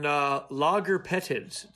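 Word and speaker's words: lagerpetids: Now, an article came out yesterday, on logarpetids. Did I say that right? Logarpetids? – Yes.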